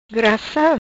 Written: Grassa

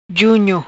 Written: Junio

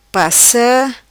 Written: Passa

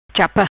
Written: Chapa

